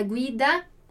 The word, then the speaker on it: guida: Então, una guida, um guia. Mas aqui a gente tem a diferença da pronúncia do G, o I, né? Então, guida e guia, d'accordo?